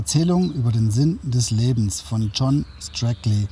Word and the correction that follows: Strelecki: Äh, Strelecki. Jetzt habe ich es falsch ausgesprochen. Strelecki. John Strelecki.